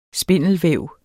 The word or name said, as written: spindelvæv